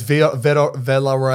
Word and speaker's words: Villarreal: How do you say your last name? Is it Villa? – I don't know. It's Villarreal. – I always say like Voreal, but it's not.